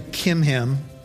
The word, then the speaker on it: Chimham: Actually, you don't pronounce the CHs. His name is actually Chimham.